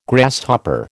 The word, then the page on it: Grasshopper: American English